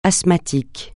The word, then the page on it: asthmatique: francais